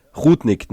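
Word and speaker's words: Rudnik: Also ich muss sagen, die Barbara Rudnick ist mir da fast, Rudig. Spinni? – Musst auf ihn scrollen. – Ja. Rudnick, habe ich schon richtig gesagt, ja.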